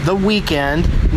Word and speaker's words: The Weeknd: The show that is taking the weekend, spelled normally, off to attend Hangout Fest in Gulf Shores, Alabama, featuring The Weeknd, no E in the second syllable, Cage the Elephant, Run the Jewels, and more, coming to you from the balcony of a hotel room in Orange Coast, Alabama.